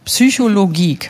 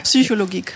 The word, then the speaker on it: Psychologik: Alle wollen das Englisch aussprechen. – Ja, ich denke, wegen des Geeks. – Ja, voll, ich verstehe es auch. – Ja, okay. Psychologik. – Psychologik.